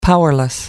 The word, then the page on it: powerless: American English